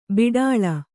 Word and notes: ಬಾಟಲಿ: bāṭali

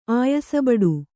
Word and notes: ಆಯಸಬಡು: kannada

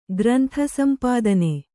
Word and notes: ಗ್ರಂಥಸಂಪಾದನೆ: grantha sampādane

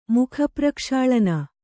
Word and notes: ಮುಖಪ್ರಕ್ಷಾಳನ: Kannada

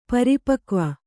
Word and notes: ಪರಿಪಕ್ವ: pari pakva

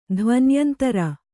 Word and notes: ಧ್ವನ್ಯಂತರ: Kannada

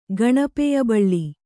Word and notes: ಗಣಪೆಯಬಳ್ಳಿ: kannada

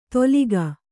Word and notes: ದೋಲಿಗ: dōliga

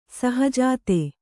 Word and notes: ಷಹಜಾದ: ṣahajāda

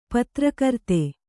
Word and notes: ಪತ್ರಕರ್ತ: patra karta

